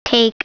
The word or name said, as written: take